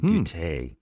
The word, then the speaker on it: goûter: which is the goûter. So it's spelled G-O-U-T-E-R, but of course you don't pronounce the R on the end.